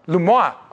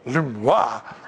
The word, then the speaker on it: Lemoine: His name is Le Moyen. Is that how you'd say that? You're French. How do you say that? Lumois. Oh, Lumois. Well, that's cool. Lumois.